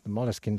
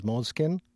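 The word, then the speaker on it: Moleskine: Having said that, though, Moleskin as a word is originally an English word. So the English pronunciation is Moleskin.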